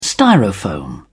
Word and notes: styrofoam: British English

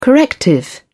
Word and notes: corrective: British English

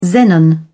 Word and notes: xenon: British English

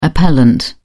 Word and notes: appellant: British English

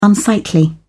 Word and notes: unsightly: British English

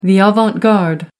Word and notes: avant garde: American English

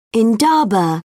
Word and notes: indaba: British English

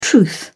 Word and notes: truth: British English

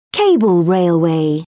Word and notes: cable railway: British English

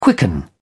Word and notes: quicken: British English

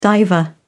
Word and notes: diver: British English

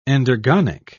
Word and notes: endergonic: British English

